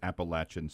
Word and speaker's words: Appalachian: Which means when he says Appalachian State, that's what it is.